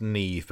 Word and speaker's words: Niamh: And this journalist, how do you pronounce N-I-A-M-H? – It's pronounced Neve, but I'm just going to say Niam.